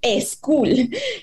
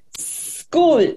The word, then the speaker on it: school: porque empieza con S y después una CH esa S no hay una vocal antes, entonces siempre decimos school. Y la pronunciación correcta es school.